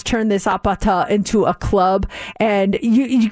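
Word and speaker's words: apata (아파트): And in Korean, it's pronounced apata, and that's what they're saying in the song's chorus.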